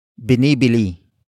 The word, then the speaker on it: binibili: And that action is binibili. Binibili. Binibili. Binibili is spelled as B-I-N-I-B-I-L-I.